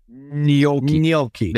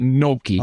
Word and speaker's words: gnocchi: Gnocchi. There you go. Not gnocchi, or whatever you called okay.